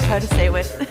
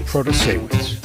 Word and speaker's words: Protasiewicz: I'm going for Judge Janet Judge Janet Protasiewicz. – Janet Protasiewicz for Supreme Court. Protasewicz.